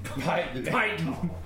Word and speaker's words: Piton: Piton. Says, in Mirabar dialect, it's Piton. – You can tell the locals from the out-of-towners by the way they pronounce it.